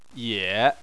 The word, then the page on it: ye: 3